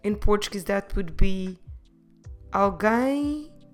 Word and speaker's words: alguém: The word alguém. Have you heard this word before? Alguém. Como se escreve alguém? A. L. G, U, E, com acento, M, alguém. Notice that the letter U is silent.